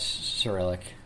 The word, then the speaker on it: Cyrillic: Is that acrylic? – Yes, Cyrillic. – Cyrillic, is that how you say it? – You said acrylic.